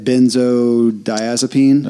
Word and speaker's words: benzodiazepine: Benzodiazepine.